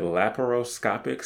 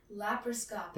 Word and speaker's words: laparoscopic: And I have written down, correct me if I'm like saying this wrong. – Okay. – Is it laparoscopic surgery? – Laparoscopic. – Laparoscopic. – Yes.